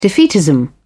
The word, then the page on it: defeatism: British English